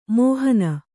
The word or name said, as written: ಮೋಹನಿ